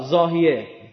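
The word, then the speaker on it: ضاحیه: داهیا به انگلیسی زاد، زاهیه. این ز و انگلیسی د تلفظ میکنن.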